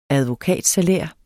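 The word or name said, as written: ADVOKATSALÆRER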